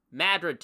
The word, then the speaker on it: Madrid: Okay, if you live in Anson, Avon, Carrabassett Valley, Carthage, East Central Franklin, Freeman Township, Kingfield, Madrid Township, and yes, those of you not from Maine, it is pronounced Madrid here, even though it's spelled just like the city in Spain.